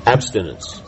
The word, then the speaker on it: abstinence: No, Bhaktivinoda, of course, talking about tolerance and abstination. Is that how you say it? – Abstinence.